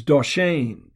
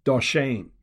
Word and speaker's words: dashen: Now, the word that is translated prosperous in Psalm 22, verse 29, is doshen. That's spelled D-A-S-H-E-N when you transliterate from the Hebrew alphabet into the English alphabet, doshen.